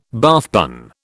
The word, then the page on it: Bath bun: British English